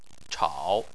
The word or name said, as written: chao3